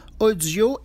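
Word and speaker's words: OHdio: Ça s'appelle Audio et ça s'écrit OH. DIO.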